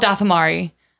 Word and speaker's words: Dathomari: The original Dothmari or something like that. So I can't remember. – No, it's okay. They're definitely called Dathomari.